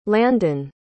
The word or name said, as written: landan